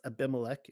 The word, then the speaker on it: Abimelech: And all the men of Shechem gathered together in all the house of Milo and went and made Abimelech, He would be pronounced Abimelech in the English, and made Abimelech king by the plain of the pillar that was to Shechem.